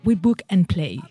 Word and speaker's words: We Book and Play: Bueno, a ver si me sale mejor. We Book and Play. – Mucho mejor.